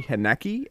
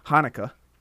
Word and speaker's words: Haneke: I always want to say Haneke, and that's probably the American in me, the you know, northeastern American in me that wants to say it like that. But Haneke, you might as well just say it like that.